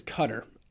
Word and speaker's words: Qatar: I think it's Qatar, actually, is the correct pronunciation.